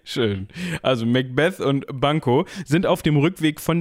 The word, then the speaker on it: Banquo: Bekommt das aber zusammen mit Banco? Banco? B-A-N-Q-U-O. Banco.